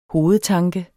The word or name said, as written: hovedtanke